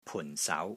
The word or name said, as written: PUN4 SAU2